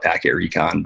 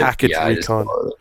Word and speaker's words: PKT Recon: Yeah, I spell it that way. I call it Packet Recon, but Packet Recon.